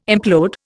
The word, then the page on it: implode: Indian